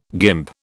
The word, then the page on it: guimp: British English